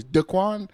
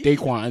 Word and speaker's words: Daequan: But like when you, so when they read my name on attendance, it's always Daequan. And I'm always like, no, it's Daequan.